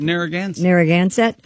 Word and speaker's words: Narragansett: The Narragansett, how do you say this? Narragansett Police Department. – Narragansett.